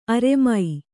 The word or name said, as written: ಅರಮ